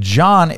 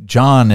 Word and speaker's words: John: That's why our John has an H in it. It's like, why is there an H? The H is silent, right?